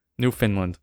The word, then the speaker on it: Newfoundland: Newfoundland, whatever it's pronounced as. Newfoundland. – Newfoundland. Okay. Like the dog.